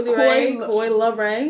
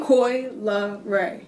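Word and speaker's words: Coi Leray: So I didn't know. It's Coy Leray. – Coy Leray. – Coy Leray. – There you go. – Okay. – That's how you say it.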